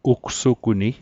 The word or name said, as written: ugsuguni